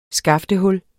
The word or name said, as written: skaftehul